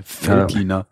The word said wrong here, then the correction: Veltliner: Ja, nein, grüner Veltliner.